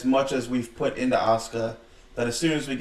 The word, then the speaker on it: Asuka: I'm thinking Bayley goes for something early that gives Asuka the win, keeps the belt for her.